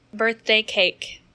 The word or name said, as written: birthday cake